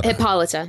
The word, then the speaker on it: Hippolyta: So Hippolyta? How do you say her name? – Hippolyta. – Hippolyta. Oh, that makes sense now.